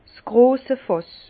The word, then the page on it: s grosse Fàss: Bas Rhin; Herrlisheim